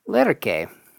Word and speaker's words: Lerche: And then we heard from Sandra Lerche, or that's not really how it's pronounced at all, but that's kind of the American version. But if you're curious, this is what I'm told. – Sandra Lerche. – Lerche. Yeah, there you go.